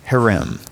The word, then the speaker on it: herem: It's actually a Hebrew word that is transliterated H-E-R-E-M. So it's one word, H-E-R-E-M. That's not how you spell it in Hebrew, but when you have the phonetic sound into English, that's gonna be harem.